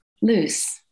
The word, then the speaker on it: Luce: In your first paragraph, you describe Luce, and am I pronouncing that? Is it Luce or Lucy? – Luce.